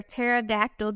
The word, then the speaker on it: pterodactyl: Because it has a silent P. Because pterodactyl is spelled with a P.